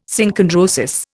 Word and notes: Synchondrosis: Indian

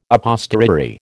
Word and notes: a posteriori: American English

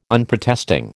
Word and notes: Unprotesting: American English

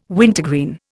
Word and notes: wintergreen: Indian

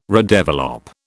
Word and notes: redevelop: British English

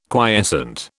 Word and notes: quiescent: British English

